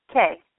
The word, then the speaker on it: Kay: Hi, this is Kay. How are you? – I'm fine. Can you say your name again? – Kay. – K-A-Y.